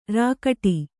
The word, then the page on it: ರೇಗಡಿ: rēgaḍi